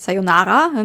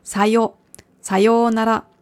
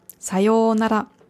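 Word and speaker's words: Sayonara (さようなら): Vorsicht bei der Aussprache, also nicht Sayonara, nicht auf dem Nara, sondern auf dem Sayonara. Sayonara.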